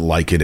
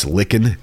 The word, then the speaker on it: Lichen: And then she corrected my pronunciation on several local names. It wasn't Lichen, it's Lichen.